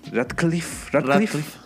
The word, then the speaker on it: Radcliffe: ¿Cómo se dice Danny Radcliffe? – Radcliffe.